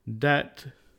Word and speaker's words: debt: With silent letter B. There are only 2 common words ending in BT, debt and doubt.